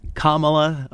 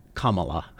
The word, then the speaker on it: Kamala: The justice did have the emphasis on the wrong syllable there. The correct pronunciation is Kamala. The vice president has said herself, just combine the punctuation mark comma with la and you got Kamala.